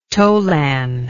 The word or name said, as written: tolan